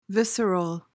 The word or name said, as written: visceral